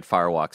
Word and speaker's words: Firewalk: And again, the thing that I always think about when watching that PlayStation conference is like, we have friends that work at Firewalk Studios.